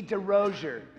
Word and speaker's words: DeRozier: How do you say your last name? Oh, that's easy. DeRozier. The American way.